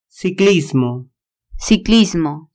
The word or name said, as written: ciclismo